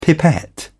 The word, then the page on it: pipette: British English